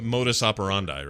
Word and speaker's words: modus operandi: Uh, modus operandi, right? Is that how you say it? – Yeah, it is.